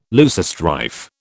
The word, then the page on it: loosestrife: British English